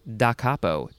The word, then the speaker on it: da capo: Okay, our next word is, I think it's pronounced da capo. Da capo. Two words. D-A is the first word, and then C-A-P-O. It's not capo like I thought it would be.